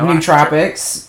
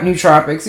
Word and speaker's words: nootropics: How do you say nootropics? – No, nootropics. Yep, nootropics. You said it right.